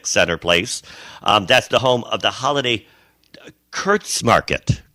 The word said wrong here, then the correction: Kerstmarkt: Kurtzmarkt, I believe they pronounce it. Okay. Kerstmarkt.